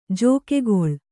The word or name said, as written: ಜಕ್ಕುಗೊಳ್